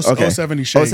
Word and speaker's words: 070 Shake: Yeah, 070 Shake.